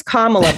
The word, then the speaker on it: Kamala: I learned it's Kamala, by the way. So if you do meet her, you have to pronounce that, you put the emphasis on the first A, or else I don't know.